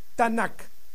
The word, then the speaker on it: Tanakh: TNK, la Tanakh hebrea. Recordemos que el hebreo es una lengua de origen consonántica. No existían vocales. Así es que TNK se pronuncia Tanakh.